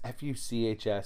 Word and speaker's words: Fuchs: Actually, it's Fuchs.